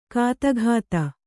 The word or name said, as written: ಗದುಗದ